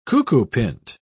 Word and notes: cuckoopint: British English

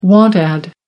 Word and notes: want ad: American English

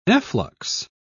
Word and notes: efflux: British English

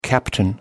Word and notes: captain: American English

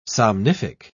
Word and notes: somnific: British English

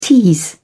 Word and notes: tease: British English

